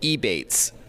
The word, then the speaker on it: Ebates: Another app, the one that focuses more on online shopping that I love, is called Ebates. It rhymes with rebates, but no R.